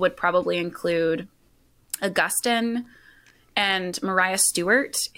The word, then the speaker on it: Maria: Mariah Stewart. It's spelled like Maria, but I learned from Jasmine Holmes that it's pronounced Mariah Stewart.